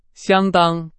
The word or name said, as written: 相当